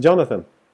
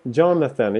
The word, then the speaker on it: Jonathan: Uh, it's uh, Jonathan. Uh, Jamaica? No. John, Jonathan. Jamaica? No, no. John, Jonathan.